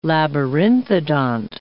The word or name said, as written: labyrinthodont